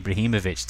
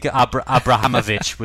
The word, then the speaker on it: Ibrahimovic: So I think Abrahamovic was done a couple of times, and Zoltan was sort of offered up a few times.